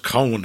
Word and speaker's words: Coen: Although I think it's usually pronounced Cohen, apparently. – Well, they're from Minneapolis. – It doesn't make sense. Then the N and the E should be transposed.